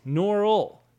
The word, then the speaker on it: Norrell: The host frequently mispronounced a lead character's name. It's like this, Norrell, not a Frenchie, Norrell.